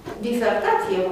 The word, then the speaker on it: disertație: Pronunțăm, de fapt, disertație, dacă nu suntem suficient de atenți, da? Și îngrâncenați așa să pronunțăm cumva academia. Scrie disertație. Dar firesc este să pronunți un S intervocalic ca Z.